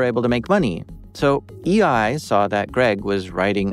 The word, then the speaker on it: eEye: It's spelled E-E-Y-E, EI.